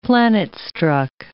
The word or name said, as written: planet-struck